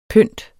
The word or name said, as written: pynt